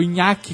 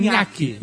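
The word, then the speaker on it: Ntuaki: O Nhaque que eu falei, que é assim que se escreve, e... N-T-U-A-K-I. É exatamente assim. Nhaque.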